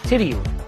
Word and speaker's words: Tidioute: Three syllables, stress on the first, and the final part rhymes with hoot, not out. Tidiu.